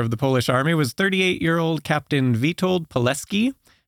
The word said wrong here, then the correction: Pilecki: who I'm probably going to pronounce wrong his name a lot in this one because I want to say Pilecki. – I'm pretty sure it's Pilecki.